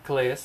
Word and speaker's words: Calais: Calais.